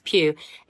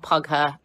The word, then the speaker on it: Pugh: You say it like that, Florence Pugh. It's like Hugh, but with a P. No, it's not Pugher, and no, it's not Pooh.